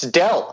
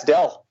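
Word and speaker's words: Doell: But yeah, it's Dell. It's a silent O.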